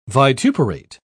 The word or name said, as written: vituperate